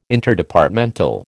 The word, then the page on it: Interdepartmental: American English